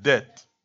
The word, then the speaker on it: debt: How do you pronounce it? It's just bad. Debt. Debt. Debt. Is the B silent? Okay, debt.